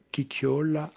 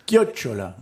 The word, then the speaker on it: chiocciola: Recevoir une chiocciola. – Chiocciola. – Chiocciola. – Chiocciola. Oui. – Merci. C'est un peu difficile à prononcer oui, en italien.